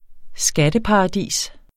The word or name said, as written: skatteparadis